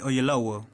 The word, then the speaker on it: Oyelowo: David Oyelowo. Tiene un nombre así rarito. ¿Cómo se pronuncia? No sé. – David Oyelowo. – Eso.